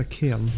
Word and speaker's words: cairn: My cairn. So I've been asked to spell it every time I see it, but it's a cairn.